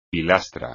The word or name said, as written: pilastra